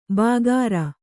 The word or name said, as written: ಬಾಗಾರ